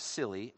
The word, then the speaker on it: Scilly: But he was anchored at one point in a place called the Isles of Scilly, S-C-I-L-L-Y, that is how you pronounce it, off the west coast of England.